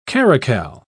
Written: caracal

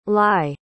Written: lai

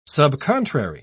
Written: subcontrary

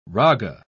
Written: raga

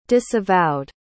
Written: disavowed

